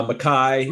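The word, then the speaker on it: Makhi: How do you say his Mackay, Mackey Mitchell? – Uh, Mackay, Mackay Mitchell, Yeah, Mackay and Makhel against Demarr Langford and Makai Ashton Langford.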